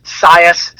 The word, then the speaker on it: Ksiez: You know, you could say Ksiez, Sias, Ksiez.